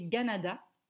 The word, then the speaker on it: Kanata: Ça provient, ça vient de l'iroquois, alors Kanata, qui est en fait prononcé Ganada, okay.